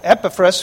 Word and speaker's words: Epaphras: Epaphras, by the way, I'd rather say Epaphras, but it's really the accents on the first syllable. Epaphras, who is one of you, a servant of Christ, saluteth you.